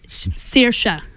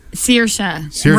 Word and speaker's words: Saoirse: We have Cate Blanchett for Carol, Brie Larson for Room, Jennifer Lawrence for Joy, Charlotte Rampling for 45 Years, and, I always say her name wrong. – It's Saoirse. – Saoirse Ronan.